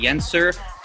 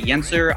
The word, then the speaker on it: Yencer: Well, I'm most active on LinkedIn, so you can just look up Jeremy Yenser on LinkedIn. My last name is spelled Y-E-N-C-E-R, but I'm also on Facebook and Instagram. If you just type in Jeremy Yenser, I believe I'm the only Jeremy Yenser in the world, so it should be pretty easy to find me.